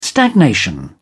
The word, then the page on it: stagnation: British English